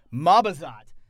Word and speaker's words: Mabazot: Mabazit? Is that right? Mabazot. I knew I said it wrong. I'm like, wait a minute, that's not right. Mabazot. Mabazat. well, well, if you want, but we're satisfied with the Mabazat syndicate's offers. All right. Eh, if you want, but we're satisfied with the Mabazot. Mab, how do you say it? Mabazot. So it's all the A's or O's.